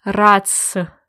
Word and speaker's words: РАЦС: РАЦС.